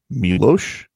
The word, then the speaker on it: Miloš: Miloš. – Miloš. – It's got the S with the thing over it.